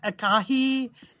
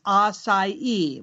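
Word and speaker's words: açaí: Some people's, it's not acai. It's not acai. It's not acai. It is acai with a soft C and stress on the last syllable.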